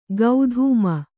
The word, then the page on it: ಗೌಧೂಮ: gaudhūma